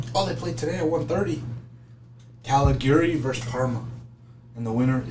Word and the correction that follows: Cagliari: Cagliari. Cagliari. My bad, I said it wrong.